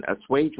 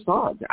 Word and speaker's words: assuage: When I was a little child, I was reading on a much higher level, and I used to study, and assuage was one of the words that was a part of my vocabulary. But I used to call it a song. I never knew how to say it.